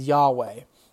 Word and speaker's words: Yahweh: But the way that we believe you pronounce it is Yahweh. And so every time you see L-O-R-D, Lord, all caps in the Old Testament, you can say Yahweh.